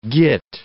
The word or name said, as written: git